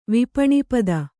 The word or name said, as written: ವಿಪಣಿಪದ